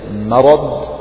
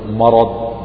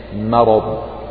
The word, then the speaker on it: مرض: فيقول لك يعني إذا نطقت بكلمة مرض مرض فرقق الميم، ولا تقل مرض بتفخيمها، لا تقل ما ولكن قل ما، مرض مرض مع المحافظة على تفخيم الراء والضاء.